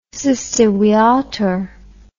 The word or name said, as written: siste viator